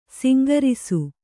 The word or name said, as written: ಸಂಘರ್ಷ